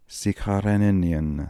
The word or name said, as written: Sikharenenian